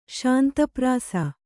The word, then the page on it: ಶಾಂತಪ್ರಾಸ: Kannada